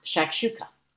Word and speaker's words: shakshuka: Either way, it's pronounced shakshuka.